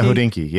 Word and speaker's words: Hodinkee: I read it all the time, but is it pronounced Hodinkee? – Hodinkee? Yeah, Hodinkee. Yeah. – That's Hodinkee. Correct.